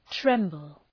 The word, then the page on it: tremble: ‘trembəl